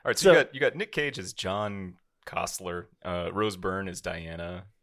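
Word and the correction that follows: Koestler: I think it's pronounced Kessler.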